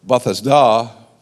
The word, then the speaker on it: Bethesda: There's a pool there by the sheep's gate called, and I always learned it, Bethesda. The other day, just for the fun, I went and looked out the pronunciation. It's Bethesda is the way you actually would say it in the Aramaic.